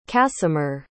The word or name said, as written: kasimir